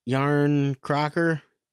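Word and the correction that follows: Järnkrok: Oh, the former friend, Yarn Croak.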